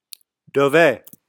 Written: dove

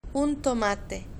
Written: un tomate

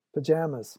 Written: pyjamas

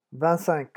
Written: vingt cinq

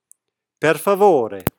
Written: perfavore

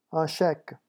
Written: un cheque

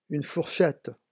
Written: une fourchette

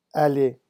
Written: aller

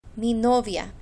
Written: mi novia

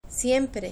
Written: siempre

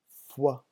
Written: foie

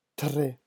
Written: tres

